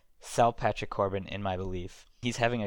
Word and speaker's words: Patrick Corbin: One thing I want to talk about is two of the premier pitchers of this year, Garrett Cole and Patrick Corbin. I assume I pronounced those names correctly. – You're 2 for 2 so far.